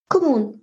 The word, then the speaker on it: Kommun: Und zwar ist das die Gemeinwohlstiftung Kommun. Oder wie spricht man das richtig aus? Kommun, genau richtig. – Genau, genau so. richtig.